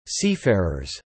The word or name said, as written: seafarers